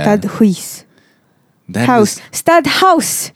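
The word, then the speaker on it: Stadthuys: It's pronounced stud house, by the way. – Stud house. – Walaupun ej dia H-U-I-S, it is house because it's Portuguese.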